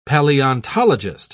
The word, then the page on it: palaeontologist: British English